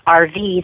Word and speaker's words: Arvee: I've got a channel called Persuasive Speaking, or you can find me through my name, which is RV. That's spelled A-R-V-E-E Robinson.